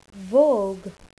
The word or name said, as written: vogue